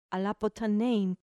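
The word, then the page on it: ἀποθανεῖν: ap ʌ t h an ɛ i ː n; apothanein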